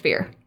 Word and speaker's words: Firre: Anyway, so the next in the line, the first of the greater Eladrin, are the Fyrr, spelled F-I-R-R-E. – F-I-R-R-E. – Fyrr. Yep. – Pronounced Fyrr.